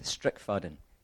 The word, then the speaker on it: Strickfaden: How do you say it? – Kenneth Strickfaden. Strickfaden. – Strickfanny? – Yeah. What was it? Strickfaden. – Strickfaden. – Yeah. Strickfaden, that's it.